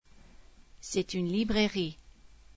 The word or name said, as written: librairie